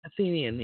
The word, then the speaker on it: Athenian: That's why we're all talking about Athenian Athenian democracy? Am I pronouncing Athenian. – It right? – Athenian, yeah.